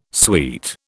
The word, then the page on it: sweet: British English